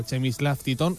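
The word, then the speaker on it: Przemysław Tytoń: El Elche sigue al portero internacional polaco, Chemislav Titon. Se pronuncia así, no lo hemos inventado, lo hemos comprobado. – Se escribe mucho más raro que se pronuncia.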